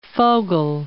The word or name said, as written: Fogel